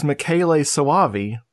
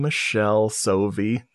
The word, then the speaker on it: Michele Soavi: my friend Brad, bless him, he's like, it's Michele Soavi. And I'm like, oh shit, I've been calling him Michelle Sovi.